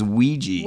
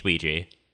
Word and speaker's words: Ouija: You know, yeah, it's spelled O-U-I-J-A, which is... – Well, it's French Ouija, yes. – Yes, Ouija. – And German Ja. – Yeah. – Yes. So it is the yes board.